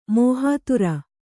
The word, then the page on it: ಮೋಹಾತುರ: mōhātura